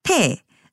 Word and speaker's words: te: Te. So it's almost like the D sound in English, te.